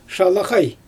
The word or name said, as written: ʃalaɣaj